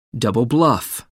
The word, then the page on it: double bluff: American English